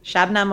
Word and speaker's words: Shabnam: Shabnam Mogharabi.